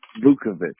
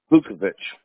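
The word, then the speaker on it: Vukovich: Vukovic. V like in Victor. – U-K-O. – V like in Victor. I-C-H. – How do you pronounce that again? I don't want to mess it up. – Vukovic. – Vukovic. So V-U-A-O. – No. – V as in? – No. No. V-U-K-O.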